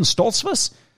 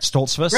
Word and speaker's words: Stoltzfus: I'm like, Trevon Stoltzfus? That's how I say your last name, right? Stoltzfus? – Right, yeah.